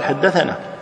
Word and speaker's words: حدثنا: لكن باللفظ لابد أن تقال حدثنا ما تقولها باللفظ ثنى هي تكتب ثنى لكن تلفظ حدثنا